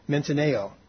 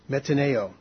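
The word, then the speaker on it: metanoeo: The Greek word translated repent is spelled M-E-T-A-N-O-E-O. M-E-T-A-N-O-E-O. And it's pronounced something like metaneo. Metaneo.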